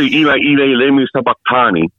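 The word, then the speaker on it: Eli Eli Lema Sabachthani: I used to say Eli Eli Lemi Sabachthani. So you were very close to that.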